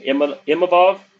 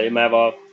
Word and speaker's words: Imavov: Match number 9 is Jordan Williams, who is the slight favorite over Nasruddin Imavov. I knew I said that wrong. Sorry about that. Imavov.